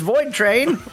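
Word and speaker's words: Voidtrain: Plan B, Terraform, Phantom Brigade, My Dream Setup, Sons of Valhalla, Voltrain. That's Void Train. I said it right the first time.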